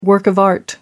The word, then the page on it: work of art: American English